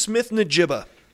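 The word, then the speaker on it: Smith-Njigba: Smith Najiba.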